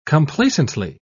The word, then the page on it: complacently: British English